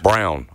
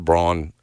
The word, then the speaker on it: Braun: Brown. I'm sorry. I'm so sorry. I fell into the trap again. – I think Christian Brown, spelled Braun, but pronounce it Brown.